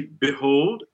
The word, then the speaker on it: behold: Here we see behold, and that's one of those words with the E prefix, including the letter E, that can be pronounced with this unstressed capital I, I. Yeah. So do not stress it, but keep it that taller, more tense sound than b, or overly tense with E.